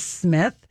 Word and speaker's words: Smythe: He's an actor, Marcus Smith, but it's spelled S-M-Y-T-H-E, only 70, but he was in Guiding Light, Search for Tomorrow, World.